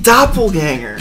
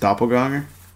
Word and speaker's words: Doppelgänger: You know, it's actually pronounced doppelganger. – You know you're full of shit. – The bell. It will gong 27 times. The gonger. – No, but in all honesty, he used to depend on gonger. – The German pronunciation is doppelganger.